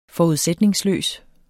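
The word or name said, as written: FORUDSÆTNINGSLØS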